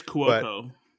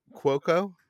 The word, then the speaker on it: Cuoco: I don't know how to pronounce her name. – I think it's Cuoco. – Cuoco. I've never seen her in,